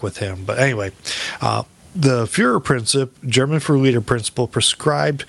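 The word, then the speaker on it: Führerprinzip: In German, it's pronounced Fuhrer Prinzip.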